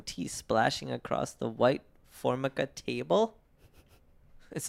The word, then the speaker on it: Formica: It's pronounced Formica.